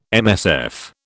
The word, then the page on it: MSF: British English